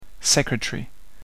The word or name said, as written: secretary